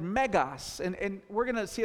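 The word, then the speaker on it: megas (μέγας): So the top word is how it looks in lowercase Greek, megas. It's pronounced megas, and it means large, great, powerful, extraordinary.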